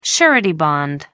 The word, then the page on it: surety bond: American English